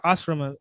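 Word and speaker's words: ashrama: Ashram or Ashram? That's Asrama. It's Asrama.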